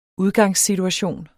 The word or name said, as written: udgangssituation